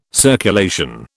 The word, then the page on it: circulation: British English